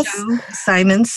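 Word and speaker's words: Chou Simons: You know, I always tell people you don't need to know that it would be pronounced Jo because it's spelled C-H-O-U. And on the mainland of China, it's Z-H-O-U. And different dialects pronounce it a little bit differently.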